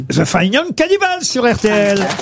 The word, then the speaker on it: Fine Young Cannibals: Yes, you have to say the five young cannibals. – Non, pas five, fine. – Ah, the fine young cannibals.